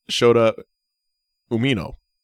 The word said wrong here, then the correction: Shota Umino: You're close. Accents in the wrong spot. – In the wrong syllable. – Shota Umino. – Umino.